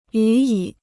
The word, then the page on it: 予以: yǔ yǐ